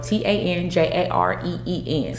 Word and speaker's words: Tanjareen: It's spelled T A N J A R E E N, Tanjareen.